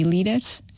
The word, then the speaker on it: líderes: Aqui o truque é ver qual é que é a sílaba tónica. Então. E perceber qual é que é a sílaba tónica, nós conseguimos saber onde é que nós temos de pronunciar. E a sílaba tónica de líder e líderes é no, na primeira sílaba, no li. Porque exatamente tem lá o acento.